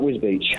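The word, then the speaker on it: Wisbech: How do you pronounce Wisbeach? – Yeah, that's right, Wisbeach.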